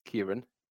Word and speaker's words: Ciarán: Now, for the record, I do know how to pronounce Ciarán because my brother is called Ciarán.